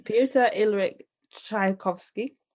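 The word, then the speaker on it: Pyotr Ilyich Tchaikovsky: The Nutcracker Suite by Piotr Ilyich Tchaikovsky.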